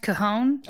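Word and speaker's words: cajón: Well, it's actually pronounced cajon.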